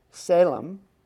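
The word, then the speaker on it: Salem (צלם): Now, image here is a Hebrew word, Salem. It's actually spelled T-S-A-L-E-M. This T is silent. L-E-M.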